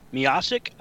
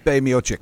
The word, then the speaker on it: Miocic: Defeating Stipe Miocic. I think that's how it's pronounced. – Stipe Miocic. – Stipe Miocic. Okay, well, I botched that one bad.